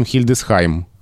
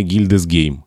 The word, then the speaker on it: Хильдесхайм: Если говорить о немецких городах, то Мангейм не Манхайм, но при этом Хильдесхайм, а не Гильдесгейм.